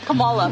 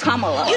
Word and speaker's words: Kamala: Earlier you said Kamala. The way she pronounces her name is Kamala.